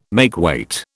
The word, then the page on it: makeweight: British English